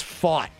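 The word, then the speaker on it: Pfaadt: It's spelled P-F-A-A-D-T. I believe it's Fought. – That sounds right, yeah.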